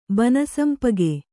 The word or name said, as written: ಬನಸಂಪಗೆ